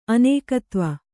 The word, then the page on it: ಅನೇಕತ್ವ: Kannada